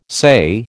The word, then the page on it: say: American English